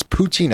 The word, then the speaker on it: poutine: It's poutine. I think it's the way it's pronounced. P-O-U-T-I-N-E. And my guest is Mercea Monroe.